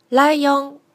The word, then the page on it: raion: Japanese